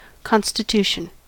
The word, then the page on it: constitution: American English